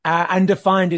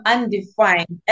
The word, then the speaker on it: Undefynd: So for the listeners, Undefined is spelled UNDEFYND. So can we jump into those two questions? – Well, yes, you had it right. I am undefined, as in the word undefined.